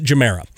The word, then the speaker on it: Gemera: a it is the Koenigsegg Gemera. Gemera, Gemera, Gemera. Um, pronounce that however you'd like. They pronounce it Gemera.